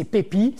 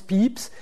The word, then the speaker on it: Pepys: c'est Pepy, Samuel Pepy. Ça se prononce Pepys, qui est un très très haut fonctionnaire de l'amirauté qui va gérer une bonne partie de la crise pour le roi.